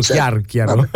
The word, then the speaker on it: Chiarchiaro: Qui entra in gioco un personaggio interessante, Chiarchiaro, è vero, come è nato questo? Chiarchiaro, sì sì, io l'ho letto con l'accento sbagliato, ma è lui, Rosario Chiarchiaro. – Sì, è così che si pronuncia.